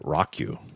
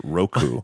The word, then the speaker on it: Roku: I pronounced it Roku, and it's in fact pronounced Roku.